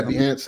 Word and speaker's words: Behance: I think it's, was it Behance? Is that how you pronounce it? – Yeah, Behance.